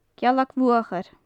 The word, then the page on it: gealag-bhuachair: gaelic